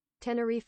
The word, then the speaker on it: Tenerife: Okay, I'm saying Tenerife, but it's probably not pronounced that way. How do I say it? Let's see, is there like a thing? Tenerife? Whatever. Pronounced. Let me give it to me. – Tenerife. – Tenerife. It's Tenerife.